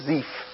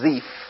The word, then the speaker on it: Ziph: And then Ziph, the name Ziph, number 2128, we would actually pronounce it more with a longer I, like a Ziph. We would call it Ziph, Z-I-Y-P-H.